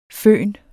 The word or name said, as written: føn